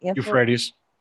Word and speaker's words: Euphrates: Euphrates.